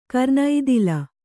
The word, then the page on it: ಕರ್ಣತಾಳ: karṇatāḷa